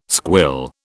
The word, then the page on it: squill: British English